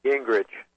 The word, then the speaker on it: Gingrich: Gingrich.